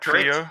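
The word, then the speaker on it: whio: You can see, as I was rightfully corrected, the FIO. Did I say it right? – FIO? Yep, that's it.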